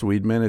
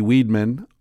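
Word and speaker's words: Weidman: I think it's pronounced Weedman. It's W-E-I-D-M-A-N.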